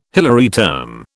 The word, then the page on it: Hilary term: British English